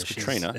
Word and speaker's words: Catriona: Was it Katrina or Catriona? – It's spelt Catriona, but it's Katrina.